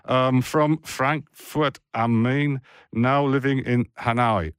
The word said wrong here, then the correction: Hanau: I think I said that right. Well, Hanau. – That's close, yeah. Hanau.